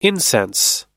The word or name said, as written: incense